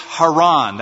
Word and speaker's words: Haran: Haran. That was the original way you'd pronounce it, with the emphasis on the last syllable.